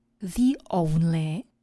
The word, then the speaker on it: the only: Ale pozor, když před něj přidáme člen určitý, v tomto případě bude stát před samohláskou, proto jeho výslovnost bude the only.